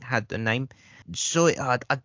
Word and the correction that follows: Zatoichi: I do know how to pronounce it, but I've forgotten. I didn't know how to pronounce it. Zatoichi, that's it. Zatoichi, I think is how you pronounce it.